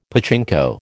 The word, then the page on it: pachinko: American English